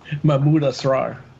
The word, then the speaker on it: Mahmoud Asrar: It has art from Mahmoud Asrar. Did I say that right? – Mahmoud Asrar. – Mahmoud Asrar. Yeah, it did sound better when you did it.